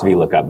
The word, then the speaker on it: VLOOKUP: Pro- I even said it wrong. It's VLOOKUP, not VS.